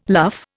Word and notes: luff: Indian

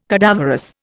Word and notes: cadaverous: Indian

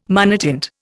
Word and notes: monotint: Indian

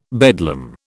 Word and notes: bedlam: British English

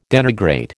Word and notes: denigrate: American English

